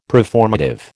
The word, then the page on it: preformative: American English